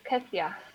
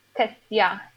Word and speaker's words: Kezia: It's Kethia. – Kethia. – Kethia. It's like a J that you pronounce it like a long I. Kethia. – Like TH, like Kethia, or? – Not H, but J, Kethia.